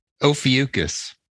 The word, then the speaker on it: Ophiuchus: Ouroboros, Ophelis, Orphobicus? What was it? – Ophiuchus. – Ophiuchus? – Ophiuchus. – Oh, Ophiuchus. – That's not a name you know the other zodiac signs are like Ophiuchus.